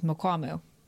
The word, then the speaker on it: Mukwamu: Gabriel from Austin would like to know what we think about, help me pronounce this, Mukwamu, correct? – Very good.